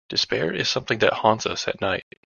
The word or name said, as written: haunts